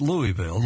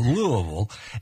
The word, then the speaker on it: Louisville: You got to say that right, not Louisville, Louisville.